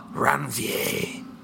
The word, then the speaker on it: Ranvier: R-A-N-V-I-E-R. Ranvier. – R-A-N-V... – I-E-R. – Yeah, Ranvier.